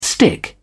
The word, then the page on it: stick up: British English